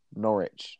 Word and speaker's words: Norwich: There's a silent W. – Yeah. Norwich.